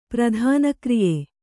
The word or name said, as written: ಪ್ರಧಾನಕ್ರಿಯೆ